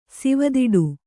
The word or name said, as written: ಸಿವದಿಡು